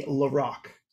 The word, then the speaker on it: LaRocque: And I was officially corrected. King Diamond guitarist is pronounced Andy LaRocque.